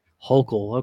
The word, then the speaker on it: Hochul: Well, see, what we were kind of touching at there is that the good old governor there, Kathy Hochul? I don't know how to say her name there. Don't really Hochul. – Care. Hochul, okay.